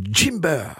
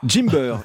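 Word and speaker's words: Gimber: C'est alors, je vais le prononcer à la béarnaise, du Jimber. – Jimber, Jimber.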